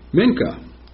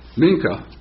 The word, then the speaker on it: minchah: The regular daily morning and evening sacrifices included the mincha. And I'm not sure if I'm pronouncing that right. It's a Hebrew word. M-I-N-C-H-A-H.